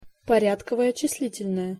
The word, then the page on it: porjadkovoe cislitel-noe: Russian